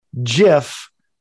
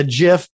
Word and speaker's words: GIF: It says if you look it up, it's pronounced GIF like... – J-I-F. – J-I-F, like the peanut butter. It says it, right. – Guy who invented it, it's a GIF like Jiffy peanut butter.